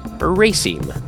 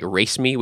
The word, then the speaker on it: raceme: That is spelled R-A-S-E-M-E. It actually looks like race me, which is kind of cool. Hey, race me. But no, it's pronounced raceme.